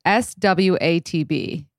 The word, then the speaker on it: SWATB: So tell me a little bit about, I don't even know how to pronounce it, SWATB. – Ah, SWAT B. – SWAT like B. – SWAT B. Like I say it's like a SWAT team, but SWAT and then the letter B.